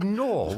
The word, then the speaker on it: Knorr: The company is called Knorr with a K, a silent K.